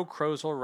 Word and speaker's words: Crozel: Saturday, July 3rd, by Joe Crozel, rhymes with puzzle.